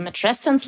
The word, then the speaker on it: Matrescence: Im Englischen spricht man schon länger von Matrescence, also Matrescence like Adolescence, Muttertät wie Pubertät.